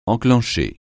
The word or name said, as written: enclencher